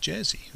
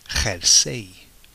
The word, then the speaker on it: jersey: Now, what's interesting about this, a jumper or a jersey, the Spanish just use the word jersey. Okay, but they don't pronounce it jersey. Of course they wouldn't. They pronounce it jersey. Jersey. So all you have to imagine is a hairy jumper.